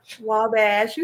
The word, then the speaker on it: Wabash: Wabash? Am I saying that right? – No, she's saying no. – How do we say it? – Wabash. You said it Wabash. Right Monday night.